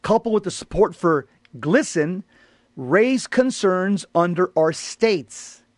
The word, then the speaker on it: GLSEN: The letter noted that Target provides financial support to an organization called GLSEN, pronounced Glisten, mm-hmm.